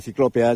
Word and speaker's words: ciclópea: Y, por ejemplo, yo ahora mismo estoy al lado, literalmente al lado, de lo que fue la muralla de la ciudad, muralla ciclópeda, ciclópeda. Creo que lo he dicho bien, no lo sé. – Sí, es la muralla ciclópea ya del siglo IV,